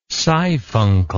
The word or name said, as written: siphuncle